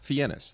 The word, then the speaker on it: Fiennes: So his real name is Fiennes. – Fiennes. That's how you pronounce it.